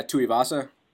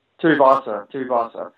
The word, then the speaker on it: Tuivasa: And from what I've seen from Tai, I don't know how to say his last name, Tuivasa, is that how you say it? – Yeah, Tuivasa. – Tuivasa.